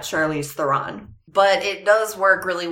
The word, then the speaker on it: Charlize Theron: So it technically is pronounced Charlize Theron. – That was really good. – With a rolling R and everything else squashed into one syllable.